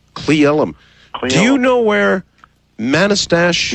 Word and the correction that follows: Manastash: Yeah, it's pronounced Manastash.